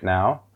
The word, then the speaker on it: nord: And I'll, so I'll just go through it now. The, so whenever you start with north, let's start with north. North is pronounced no. No.